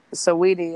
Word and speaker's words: Saweetie: Um, I never knew how to pronounce her name though. You you just told me just now though. That's tight. – Saweetie, yeah, she's she's the truth for sure.